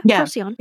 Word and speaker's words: Procyon: And I don't even know if I'm pronouncing that right. Procyon, I guess so. – I believe you are. Yeah. Procyon.